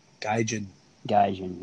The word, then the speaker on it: gaijin: Yeah, I see Joe Omega facing Naito, which gives Naito a gate, whatever. Yeah, I pronounce it wrong. But, Gaijin, whatever. – Gaijin. – Yeah, I got it right the first time.